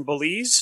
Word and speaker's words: Belize: You know, I'll do my show from Belize.